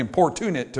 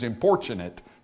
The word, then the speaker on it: importunate: It looks like importunate to me, but for some weird reason in English, the actual pronunciation is importunate. Don't ask me why, because we pronounce it importunity.